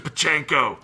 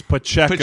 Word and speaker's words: Pacheco: Pacheco. Who is just, you added somebody Pacheco. like what a name too, right? Like, God, what a badass. Throw it to Pacheco. – It's Pacheco.